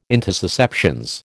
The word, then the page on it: intussusceptions: American English